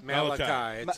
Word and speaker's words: Malachi: Malachi.